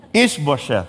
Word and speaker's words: Ish-bosheth: Okay, so Ish-bosheth, right? But he was so useless. And so Abner knew this, and so he's preparing himself probably to take over the throne. But in chapter 3, Ish-bosheth confronted Abner.